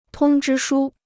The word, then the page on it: 通知书: tōng zhī shū